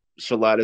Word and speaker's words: Sileadh: Shalad is how it's pronounced, and it means flowing rain shower.